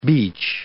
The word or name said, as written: beech